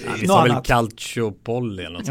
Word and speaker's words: Calciopoli: Och jag kan inte uttala detta, men det är ju den här italienska Calciopoli. – Calciopoli, så säger man. – Calciopoli.